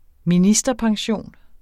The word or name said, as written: ministerpension